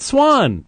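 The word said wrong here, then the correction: sawm: It's saum. I mean, I'm sorry, but at least I pronounce it correctly.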